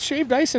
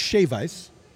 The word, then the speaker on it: shave ice: There's ice cream hiding underneath shaved ice in Hawaii? – First of all, it's shave ice. That's how they say it.